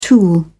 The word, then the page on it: tool: British English